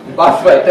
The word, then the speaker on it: Buffett: Buffet or whatever, how you pronounce his name? Buffett, thank you.